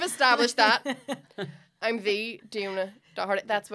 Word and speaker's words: Doherty: It's we give it two syllables. Doherty, like Doris Day.